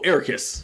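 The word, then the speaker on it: Eraqus: Get him away from how do you say his name again? Erisk? Square guy. – Oh, Ericus. – Ericus. – Yeah. – Ericus. – For some reason, I thought you were going for, like, Eris.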